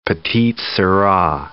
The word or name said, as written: petite sirah